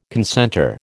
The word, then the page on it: concentre: American English